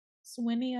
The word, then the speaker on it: Swinny: And then in June, Peggy, how would you say this? S-W-I-N-N-Y, Sweeney? – Sweeney, I would say.